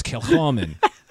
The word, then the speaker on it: Kilchoman: The H is silent. What kind of like more of that H, so it's Kilchoman.